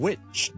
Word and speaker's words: which: 給多幾個例子大家，譬如 which w-h-i-c-h 哪一個 which，同埋一個巫婆咁啦，witch which which which，同埋 which，有啲人會讀成 which 嘅，so which is right，咁你可能就會聽到咧，某啲人就讀成 which is right，唔需要一定學嘅呢個音，但你可以知道點解有啲人會咁樣讀。